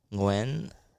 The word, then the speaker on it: Nguyen: I think the proper pronunciation is Nguyen. – Is it really? – It's like a tonal language, so it's a bit trickier.